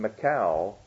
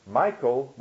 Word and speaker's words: Michal: Evidently, Michael, probably in the Hebrew, the word would have been Michal, but we pronounced Michael was Saul's daughter who had been given in marriage to David, and she helped David escape.